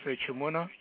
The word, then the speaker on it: Echemuna: My name is Charles Echimuna. Last name is spelled E-C-H-E-M-U-N-A.